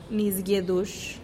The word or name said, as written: nis giedux